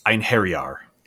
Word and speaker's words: Einherjar: Einherjar.